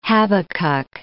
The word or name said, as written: Habacuc